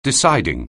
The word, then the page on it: deciding: anglais